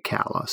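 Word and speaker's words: Calla: I picked up a audiobook of Wolves of the Cala, and I have not started listening too far in, but both the introduction to the audiobook as well as the reader both say Cala.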